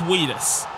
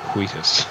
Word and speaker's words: Wheatus: Number four, this is Weedus with Teenage Dirtbag. – You gotta pronounce the H. It's like Wheatus.